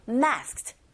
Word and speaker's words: masked: masked로 발음되지 않고 masked, masked. 빨리 뒷발음을 t 이렇게 하시면 되고요.